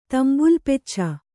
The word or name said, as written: ತಂಬುಲಪೆಚ್ಚ